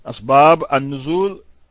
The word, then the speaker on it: Asbab An-Nuzul: Asbab An-Nuzul with a Z, not an S.